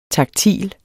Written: taktil

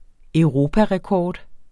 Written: europarekord

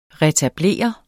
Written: retablere

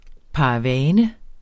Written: paravane

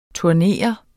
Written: TURNERES

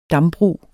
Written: dambrug

